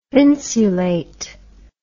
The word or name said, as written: thinsulate